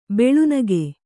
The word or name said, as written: ಬೆಳುನಗೆ